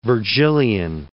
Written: Vergilian

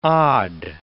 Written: od

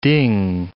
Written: ding

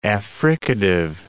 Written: affricative

